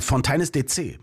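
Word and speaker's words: Fontaines D.C.: Und du hast es wirklich falsch ausgesprochen, weil es ist Fontaines DC.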